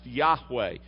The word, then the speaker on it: Yahweh: Used to in our Bibles, we'd see it as Jehovah, or in our hymnals, we would see it as Jehovah. Nowadays, you more often hear it pronounced Yahweh, but it's the proper name of God.